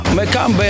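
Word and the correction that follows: Mbappé: jak on? – Mbeppe. – Mbeppe.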